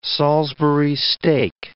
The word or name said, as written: Salisbury steak